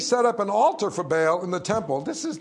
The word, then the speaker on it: Baal: Now, you could pronounce this a few ways. Baal, Baal. We'll do Baal because we're in the south.